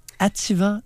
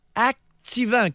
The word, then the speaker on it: Ativan: Ativant les copains, il y a 10 ans, il y a une décennie et plus, Activant, comme prononcent beaucoup de personnes. Ils mettent un C. J'ai jamais compris le activant. C'est activant. Il n'y a pas de C après l'A.